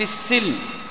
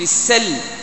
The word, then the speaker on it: السلم: هذه اللفظة هي على قراءتين، على قراءتنا قراءة حفص عن عاصم نقرأها بالكسر في السلم، وفي قراءة غيرنا تنطق بالفتح. يا أيها الذين آمنوا ادخلوا في السلم، في السلم بفتح السين، ونحن في قراءتنا بالسلم.